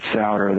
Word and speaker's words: Sauder: And by Richard Sauder. That's last name is spelled capital S as in Sam, A-U-D-E-R.